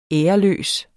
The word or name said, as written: æreløs